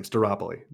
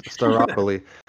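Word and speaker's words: Stirpoli: So how do you feel about Pepe Stripoli? – Yeah, man. Well, I butchered his last name. Steropoli. There you go. – Steropoli.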